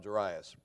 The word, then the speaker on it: Jairus: And he was, some call him Jairus. My Bible puts the accents on the second syllable there, so we'll call him Jairus.